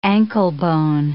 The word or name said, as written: anklebone